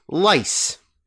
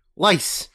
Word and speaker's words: Lay's: It is the Margarita, which is the South American version of Lay's, which they pronounce lice. – What? – Yeah, lice is how it's pronounced.